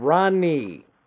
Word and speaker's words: Ronnie: Ronnie.